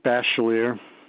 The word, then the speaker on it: Bachelier: Richard Bachelier. It's spelled B as in baseball, A-C-H-E-L-I-E-R.